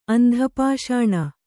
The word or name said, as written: ಅಂಧಪಾಷಾಣ